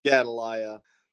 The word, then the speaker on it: Gedaliah: Even they sent and took Jeremiah out of the court of the prison and committed him unto Gedaliah. – How do you say that one? – Gedaliah.